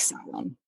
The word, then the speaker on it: Samhain: So, um, and you often see it, it's spelt the way we would pronounce it phonetically, like would be Samhain, but it's actually yep. Samhain.